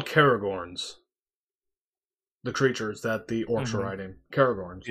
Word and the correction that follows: Caragor: And they get into a battle, and Aragorn actually gets caught on a Caragorn. That's funny. Am I actually saying the name right? Because I want to know. Hold on. Caragor, not Caragorn, Caragor.